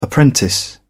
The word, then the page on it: apprentice: British English